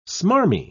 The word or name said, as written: smarmy